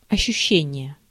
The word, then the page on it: ощущение: [əɕːuˈɕːenʲɪjə]